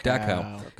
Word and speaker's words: Dachau: So his dad, Roman was in the Dachau, am I saying that Dachau. – Right? Dachau.